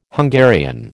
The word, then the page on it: Hungarian: American English